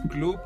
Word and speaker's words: Clupp: Así es, pues Club en primera, que se escribe C-L-U-P-P, con P P de, P de Pedro.